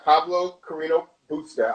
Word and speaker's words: Pablo Carreño Busta: So for the men, you know, we have Pablo Carreño Busta.